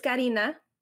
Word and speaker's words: Karina: My name is Karina, obviously intended to be pronounced in Spanish, but I've had my name mispronounced and made fun of since childhood. I've heard Katrina, Karina, Corina.